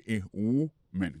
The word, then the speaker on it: woman: Prononciation c'est a woman. Quand on ou, son ou. Monsieur son woman. Pays womani, c'est dans le sens où ça. A woman, ça veut dire son femme. C'est son femme au singulier. Mais tandis que c'est le mot femme au pluriel, qu'on n'a pas prononcé woman, encore c'est women, many women.